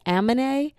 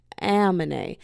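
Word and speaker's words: Aminé: And I was like, anime? And he was like, amine. So now I have a running battle between me and all my friends because I've been mispronouncing this man's name.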